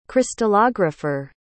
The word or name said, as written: crystallographer